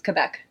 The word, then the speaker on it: Quebec: Quebec? – No, it's Quebec. It's how it's pronounced.